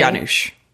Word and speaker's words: Janusz: Is it a soft J? – Janusz.